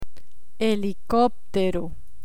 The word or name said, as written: helicoptero